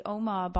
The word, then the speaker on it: Omagh: There was the Omagh bombing. – Yeah. – I'm pronouncing that right, right? – You got it.